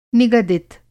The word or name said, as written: निगदित